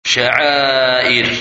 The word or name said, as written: Mashair